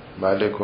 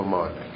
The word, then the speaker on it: مالک: گفتن دو جور میتونید بخونید، ملک و مالک. آنچه که مشهور هست الان تو قرائت هم مالک هست، ولو تو الف رو نمی نویسن اما در تلفظ می خونن.